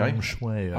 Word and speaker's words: feng shui: Feng shui, I believe it's pronounced.